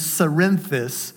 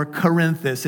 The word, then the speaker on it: Cerinthus: And here's what one of them said, and this one in particular is named Cerinthus or Corinthus. It's spelled C-E-R-I-N-T-H-U-S. This is important.